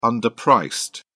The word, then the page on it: underpriced: British English